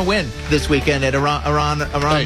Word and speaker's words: Aronimink: You mean Aronimink? Aronimink. I believe I learned how to say it correctly.